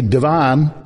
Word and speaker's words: Devon: Devon. However, it's spelled D-E-V-O-N, so you might say Devon, like they do in Chicago, but here it's Devon. Now, there was a sports car named Devon, spelled D-E-V-I-N, and it was made in California.